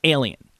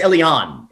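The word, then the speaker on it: Elián: Meanwhile, George Bush repeatedly refers to Elian Gonzalez as alien. – Alien. Yeah, everybody. Yeah. I think even I'm putting the stress on the wrong. I think it's Elian. It's like the stress is, you know.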